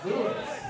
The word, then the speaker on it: Luis: Luis!